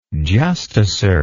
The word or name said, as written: justicer